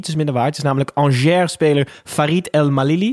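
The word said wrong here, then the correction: El Melali: Oh. – Malali. Sorry, sorry Farid. Verkeerd uitgesproken.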